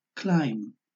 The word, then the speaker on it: climb: A veces la letra B es muda, lo que en inglés se llama silent B, y que ocurre en palabras que terminan en las letras MB. Entonces se pronuncia solamente la M y no la B final, como es el caso de las palabras lamb, cordero, comb, peineta, o climb, escalar.